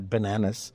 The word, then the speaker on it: bananas: Det upprepas väldigt mycket om bananas eh, som dessutom uttalas då med e så att det är bananas.